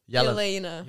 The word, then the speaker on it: Yelena: How do you say it? – Yelena. – Yelena, okay.